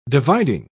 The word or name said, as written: dividing